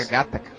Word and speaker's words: Gattaca: Outro filme bacana dessa linha política, discussão política, de controle da humanidade, é o Gattaca. Gattaca, não sei como é que pronuncia essa porra. – Pô, filmaço. Era Gattaca.